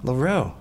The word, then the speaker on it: Loro: Is it Loro? – Loro.